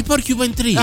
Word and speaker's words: Porcupine Tree: Porcupine Tree. – I Porcupine Tree.